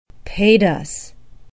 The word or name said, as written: padus